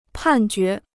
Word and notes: 判决: pàn jué

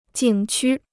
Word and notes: 景区: jǐng qū

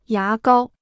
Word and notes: 牙膏: yá gāo

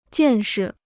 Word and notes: 见识: jiàn shi

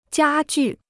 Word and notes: 家具: jiā jù

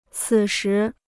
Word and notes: 此时: cǐ shí

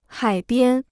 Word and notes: 海边: hǎi biān

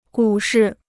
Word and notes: 股市: gǔ shì

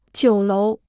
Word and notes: 酒楼: jiǔ lóu

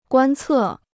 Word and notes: 观测: guān cè

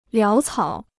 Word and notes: 潦草: liáo cǎo

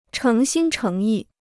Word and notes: 诚心诚意: chéng xīn chéng yì

